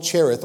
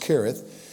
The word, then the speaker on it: Cherith: Then that's when the word of the Lord came to him saying, get away from here and turn eastward and hide by the brook Cherith, or actually it's pronounced Kerith, which flows into the Jordan.